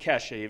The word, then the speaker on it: Kesha: Kesha, you still pronounce it Kesha, even though it's a dollar sign.